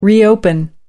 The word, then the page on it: reopen: American English